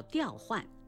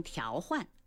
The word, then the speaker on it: 调换: 表示工作调动或上级调用财物叫上调，表示价格、利率等向上调整叫上调，调动分配叫调配，调整搭配叫调配，彼此互换、更换叫调换，不能读成调换。